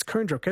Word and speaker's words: Koondrook: It's Koondrook, isn't it? – Koondrook, sorry.